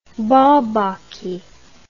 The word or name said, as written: bourbaki